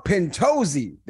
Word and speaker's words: Pintozzi: Very close. Pintozzi. – Pintozzi. There we go.